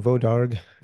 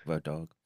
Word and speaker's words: Wodarg: Yeah, so, you know, he did the Wolfgang Wodak. Wodak? I'm not sure how you pronounce the last name. – Wolfgang Vodarg. – Vodarg.